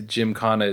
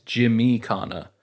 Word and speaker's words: Gymkhana: The way that it's spelled in the Ken Block thing, I think the Gymkhana is correct, but it was always Jimmy Khana whenever it was at the Scout Ranch.